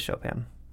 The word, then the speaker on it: Chopin: I have The Awakening by Kate Chopin. I think it's pronounced the same way. – I think it's Chopin.